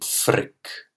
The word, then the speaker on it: Frigg: And also of another goddess her name, I don't know how to pronounce, is F-R-I-G-G. – Frick. – Frick.